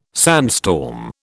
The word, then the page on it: sandstorm: British English